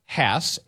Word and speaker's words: Hass: Ever wonder why you sometimes see Hass, H-A-S-S, avocados, and then other times see H-A-A-S, or Hass avocados? Wonder no more. We are happy to set the record straight. The correct spelling is H-A-S-S. It is spoken to rhyme with pass. – No. They're H-A-A-S, and it's Hass. – It's H-A-S-S, named after Postman Rudolph Hass.